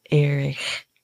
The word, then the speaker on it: Erich: It's like Erich. – Yeah. It's like I like the way you did it. – Erich.